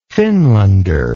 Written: finlander

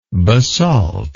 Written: basaltine

